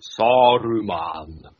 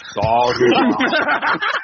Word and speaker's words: Saruman: no, it's Saruman. Saruman.